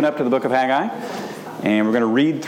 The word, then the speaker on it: Haggai: And the correct answer is Haggai. When you pronounce it in Hebrew, it's Haggai. Two syllables, that's it.